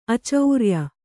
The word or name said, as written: ಆಚಾರಿಯ